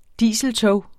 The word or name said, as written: dieseltog